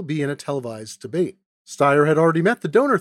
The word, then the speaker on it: Steyer: Yesterday, I pronounced Tom Steyer's name as Tom Steyer. I deeply regret the error, which I attribute to my training in the German language.